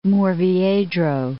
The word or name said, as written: Murviedro